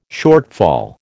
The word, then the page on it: shortfall: American English